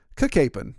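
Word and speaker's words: Cacapon: And then, of course, just over the ridge, over the mountain from him was Kakapon. They had an extra syllable there. So it was like Kakapon, Kakapon.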